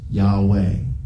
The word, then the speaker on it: Yahweh: So up to that time, it might have been more common for priests or in prayer to address God by the Hebrew divine name, which we believe is pronounced as Yahweh.